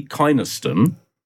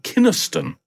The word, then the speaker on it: Kynaston: Please note that Laurie Kynaston, as I said it was, is actually pronounced Laurie Kynaston.